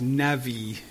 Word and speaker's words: nabi: The Hebrew word for prophet is nabi. Nabi, perhaps navi is how it's pronounced.